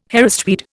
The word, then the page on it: Harris tweed: Indian